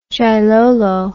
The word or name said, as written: djailolo